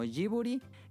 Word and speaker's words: Ghibli: El estudio Ghibli toma de nombre dos elementos de la palabra italiana Ghibli, que se puede decir dos veces, pronunciada como Ghiburi, que significa aire caliente del desierto, y puede decir también el, Ghibli como, no sé, creo que la, la forma correcta debe ser Ghiburi, este, era ese nombre porque querían darle como nuevos aires a la industria de la animación japonesa.